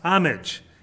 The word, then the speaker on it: homage: Okay, homage is what it's written as, but it's pronounced homage, H-O-M-A-G.